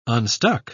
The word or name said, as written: unstuck